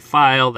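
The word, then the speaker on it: Feil: And you can find me on Twitter at Chrissy File, that's F-E-I-L, also on Letterboxd under the same name.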